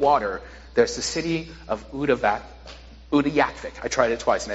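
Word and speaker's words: Utqiaġvik: I think I've said that right. I tried to write that out phonetically for myself. Utyakvik.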